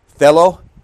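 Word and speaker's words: thelo (θέλω): The first two are a noun and a verb, thelo and thelema, G2307 and 2309. And also G1012 and 1014, which is boule and boulamai if you want the Greek words. That's how they're pronounced.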